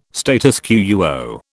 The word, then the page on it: status quo: British English